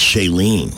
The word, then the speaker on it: Shailene: Oh, it's Shailene. – Yeah. – Sorry, I had the accent on the wrong syllable.